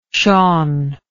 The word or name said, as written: shaun